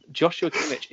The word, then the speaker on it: Joshua Kimmich: So it was Joshua Kimmich, who plays for Bayern Munich in the sport of football. – Your pronunciations are coming on very well. Joshua Kimmich is correct.